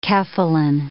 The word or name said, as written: cephalin